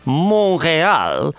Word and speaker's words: Montreal: That's pronounced if you were speaking Quebecois. Montreal.